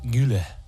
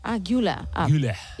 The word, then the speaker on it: Güler: Creo que Arda es Arda Güler. – Güler. ¿Y cómo he dicho? Güler. – Sí, es más bien una...